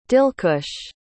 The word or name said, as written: dilkush